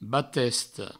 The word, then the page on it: batesta: Provençal; batesto